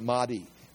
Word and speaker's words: Mahdi: I wrote it down as Mahdi. That's how you say it, Mahdi.